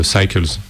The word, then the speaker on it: Cycles: Je ne sais pas si on prononce en français. – Non, c'est prononcé en anglais, c'est tout anglophone. Cycles. – Cycles, ouais.